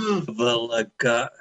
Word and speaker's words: Bvlgari: It's pronounced Bvlgari. It's BV.